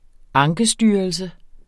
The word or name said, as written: ankestyrelse